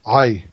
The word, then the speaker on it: Ai: Is this Ai? – Ai, yes. Ai. It's the city of Ai.